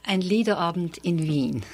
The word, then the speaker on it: Ein Liederabend in Wien: Ein Liederabend in Wien.